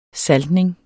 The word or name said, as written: saltning